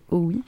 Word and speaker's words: Hauuy: Je ne sais pas comment ça se prononce exactement. – Mm-hmm. – C'est H-A-U-U-Y. – Howe, ouais, ça peut être ça, bien. À mon avis, c'est Howe. Alors, Vincent Howe, qui est donc canadien et qui est à la base concepteur de jeux vidéo.